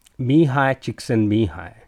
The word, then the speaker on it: Csikszentmihalyi: So there is a Hungarian-American psychologist, Mihai Csikszentmihalyi. That's how you pronounce his name.